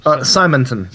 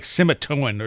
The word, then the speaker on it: Simonton: how do you say his last name? – Simonton. – Simonton. Okay, mm-hmm. I always think it's like Simitoan or something like that. – yeah, it's deceptively simple.